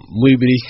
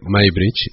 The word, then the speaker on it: Muybridge: o Muybridge, que es como se escribe, según que se dice Muybridge, y que fue un señor que empezó a determinar, gracias a la fotografía, a determinar cómo realmente se movían los seres, ¿no?